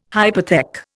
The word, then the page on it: hypothec: Indian